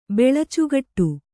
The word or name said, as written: ಬೆಳಚುಗಟ್ಟು